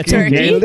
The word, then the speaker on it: Torquay: And then we're doing Burn It Down in Torquay. Is that how you pronounce it? – Yeah, Turkey. Torquay. – No, you nailed that.